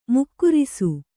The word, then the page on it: ಮೂಗೇರಿಸು: mūgērisu